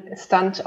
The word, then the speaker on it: Stunt: Ähm, und ich habe konsequent Stund dazu genannt und Hansi, es tut mir leid. Also das Ding wird ähm, Stunt ausgesprochen und der Oberwitz ist, du hattest das letzte Mal auch gesagt, so naja, den könnte man auch Stunt aussprechen, ja.